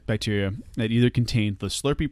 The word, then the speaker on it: SLRP: Like, it's spelled S-L-R-P, but pronounced Slurpee.